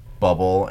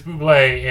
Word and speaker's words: Bublé: Are we sure his name isn't actually Bubble and he just pronounces it weird? – No, it is Bublé, and Bublé tried to explain to Ham that it's not Bubble and it doesn't really make sense.